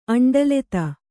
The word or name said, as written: ಅಂಡಲೆತ